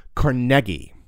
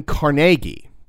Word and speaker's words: Carnegie: But the fact is, he pronounced his name Carnegie, or even Carnegie.